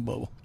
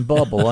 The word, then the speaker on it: Bublé: It's a joke now that he's saying Bubble. Understand, he understands that his name is Bublé, okay, yeah. But it's spelled Bubble. – In Arkansas, it's pronounced Bubble. – All right. – In Canada, it's Bublé.